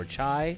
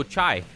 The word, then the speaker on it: chai: symbolizes, I don't know how to pronounce it, it's C-H-A-I, like Chai or Chai. – Oh, I can't even see it. Oh, Chai. – Chai, yeah.